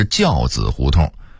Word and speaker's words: 轿子胡同: 此外还有劈柴胡同的读音应该是劈柴胡同，西河沿的读音应该是西河沿，焦子胡同的读音应该是轿子胡同，